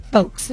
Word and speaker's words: Foulkes: Former CBS executive Helena Foulkes, pronounced Foulkes, that is, defeated Governor Dan McKee in yesterday's Democratic primary.